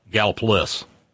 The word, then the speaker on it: Gallipolis: Just like Gallipolis in Ohio is Galpolis.